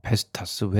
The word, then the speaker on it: 베스타스: 뭐 베스타스, 웨스테드 뭐 이런 데인 것 같아요. 발음은 정확하지 않습니다. 네. – 베스타스 정확하셨고요, 발음.